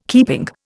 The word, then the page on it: keeping: Indian